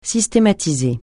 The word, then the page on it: systématiser: francais